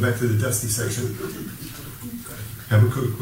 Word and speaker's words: Habakkuk: Let's turn to Habakkuk. I can't ever pronounce this. H-A-B.